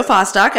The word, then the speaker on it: Fostock: Jo Fostock, as in Falalala Fostock.